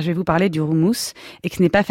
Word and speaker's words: houmous: Alors déjà, ça se prononce houmous, car en hébreu comme en arabe, il y a le H et ça se prononce R.